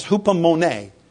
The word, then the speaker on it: hupomone: And it's hupomone. Mone, like the painter. That's how you pronounce this word.